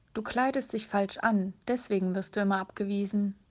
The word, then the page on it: abgewiesen werden: German